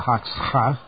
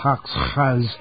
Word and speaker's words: Hoxha: In every town and village, billboards and signs glorify Mr. Haksha, I believe is how you pronounce it. Yes, something like that.